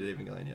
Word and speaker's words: Evangelion: Yeah, we did Evangelion, yeah.